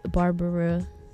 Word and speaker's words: Barbara: It's Barbara, B-A-R-B-A-R-A, Hector on Facebook.